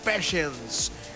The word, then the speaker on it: Patience: Passions es el título, o Patience se escribe, Passions, Guns N' Roses, 1989.